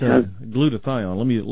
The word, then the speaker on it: glutathione: Glutathione.